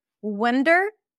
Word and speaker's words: wonder: Wander, that's an ah sound. Wander, wander, wonder, wander. So the one spelled with an O is a cup sound.